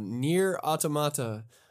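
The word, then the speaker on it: NieR: Automata: Nier Automata.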